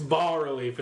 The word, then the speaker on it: bas relief: Is it similar to a bas relief? – It's bas relief. It's a silent S.